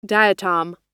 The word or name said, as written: diatom